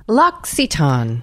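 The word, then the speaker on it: L'Occitane: So the way you pronounce the company is Lox-E-Ton. So Lox, like bagels and Lox, bright E-E-Ton, like a dark aw. Lox-E-Ton. Okay, hope this helps.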